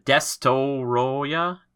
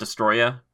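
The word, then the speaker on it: Destoroyah: but is it Destoroya? Destoroya? Not Destroya, but maybe however you want to say it.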